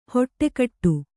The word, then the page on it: ಹೊಟ್ಟೆಕಟ್ಟು: hoṭṭe kaṭṭu